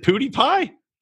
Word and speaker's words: PewDiePie: Is it PewDiePie? – Yeah, that's right.